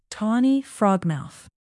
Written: tawny frogmouth

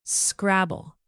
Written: scrabble

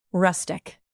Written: rustic

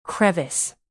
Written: crevice

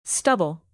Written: stubble

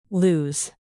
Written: lose

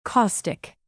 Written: caustic

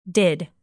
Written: did

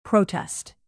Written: protest

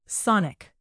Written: sonic

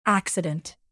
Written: accident

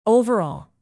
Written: overall

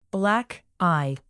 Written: black eye

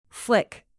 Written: flick

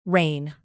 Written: reign

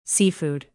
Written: seafood

